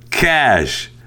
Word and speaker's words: cache: I found it ironic that you spent a lot of time criticizing the pronunciation and spelling in the story while continually mispronouncing the word C-A-C-H-E as cachet. That is how you pronounce the word C-A-C-H-E-T. C-A-C-H-E is pronounced cash.